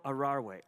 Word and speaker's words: aware: I said, Aware? He says, Aware. He says, Not aware.